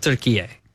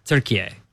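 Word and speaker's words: Türkiye: They pronounce it turkey A. – Turkey A. – It's pronounced turkey A.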